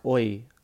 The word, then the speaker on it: hoy: But the way they say it is HOY, and that is pronounced oi, pretty much the same.